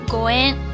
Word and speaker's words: 五円: 五円，五円，五日元。为什么投五日元呢？因为它和五円缘分的缘发音是一样的，所以很多人都觉得我投了五日元呢，缘分就会随之而来。